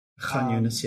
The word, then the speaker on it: Khan Yunis: Well, then pushed into Khan Yunis. I think I'm saying that right. I'm not sure. – Khan Yunis, yes.